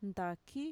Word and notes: ntaki: Mixtec

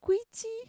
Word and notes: kuitxi: Mixtec

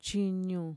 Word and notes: chiñu: Mixtec

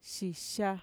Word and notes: xixa: Mixtec

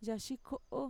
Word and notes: yaxiko'o: Mixtec

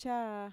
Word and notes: chaa: Mixtec

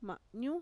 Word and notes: ma'ñu: Mixtec

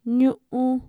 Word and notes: ñu'u: Mixtec

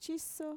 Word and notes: chiso: Mixtec

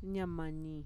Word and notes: ñamani: Mixtec

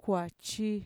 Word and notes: kuachi: Mixtec

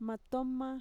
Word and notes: matoma: Mixtec

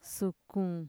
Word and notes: sukun: Mixtec

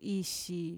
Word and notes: ixi: Mixtec

